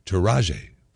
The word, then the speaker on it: Taraji: Taraji, I got to say her name correctly. Keeps throwing me. T-A-R-A-J-I P. Henson. Taraji P.